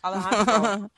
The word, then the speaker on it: Alejandro: Alejandro.